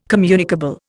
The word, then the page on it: communicable: Indian